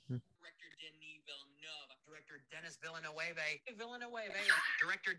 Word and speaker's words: Villeneuve: No, it's Villeneuve.